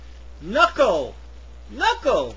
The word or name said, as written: knuckle